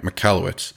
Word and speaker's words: Michalowicz: I had to sound it out in my outline here so I'd say his name right, Michalowicz.